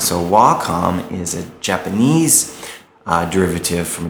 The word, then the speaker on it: Wacom: And by the way, it is pronounced Wacom.